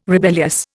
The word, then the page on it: rebellious: Indian